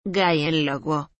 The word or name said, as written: Gìenlowo